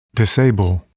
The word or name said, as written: disable